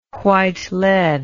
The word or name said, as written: white lead